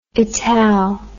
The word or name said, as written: ital